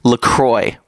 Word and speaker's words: LaCroix: And they say LaCroix rhymes with enjoy.